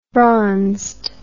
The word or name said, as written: bronzed